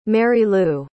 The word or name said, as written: marylou